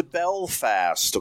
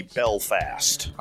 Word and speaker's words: Belfast: We started out in Glasgow, Scotland, and right from there went to Belfast. Okay, I didn't say Belfast because I was learned the correct way to say it is actually Belfast.